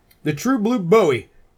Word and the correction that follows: Bowie: What? Bowie. Bowie. Sorry. It's Bowie. Gonna take me a while. I've got 35 years of mispronouncing it.